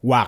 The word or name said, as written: Wak